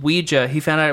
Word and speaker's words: Ouija: Ouija. I looked it up. It is pronounced both ways.